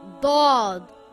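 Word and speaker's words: Dod (ضاد): Dod is also a heavy letter and should always be pronounced heavy. The closest letter would be the letter D and the letter Dal, but it is pronounced a lot heavier. The sound also comes from the back of the mouth, Dod.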